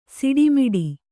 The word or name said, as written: ಸುಡುಮಿಡು